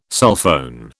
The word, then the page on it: sulphone: British English